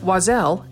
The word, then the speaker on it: Oiselle: It's pronounced Wazelle and it's spelled O-I-S-E-L-L-E. It's French for bird.